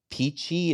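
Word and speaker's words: pici: And each town has its own, like we went to Siena and tried the peachy, it's P-I-C-I, just pronounce it peachy, pasta.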